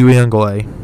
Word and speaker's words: Uiagalelei: But the quarterback play, my lack of faith in DJ Uiagalelei.